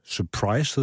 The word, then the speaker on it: Surprise: Surprise hedder det. Er det rigtigt udtalt? – Det er det.